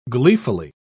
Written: gleefully

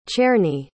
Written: czerny

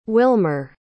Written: Wilmer

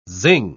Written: zing

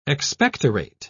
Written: expectorate

